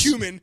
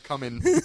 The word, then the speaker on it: cumin: Cumin. – Yeah, exactly. – Yeah, not coming.